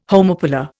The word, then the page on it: homopolar: Indian